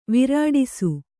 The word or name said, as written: ವಿರಾಡಿಸು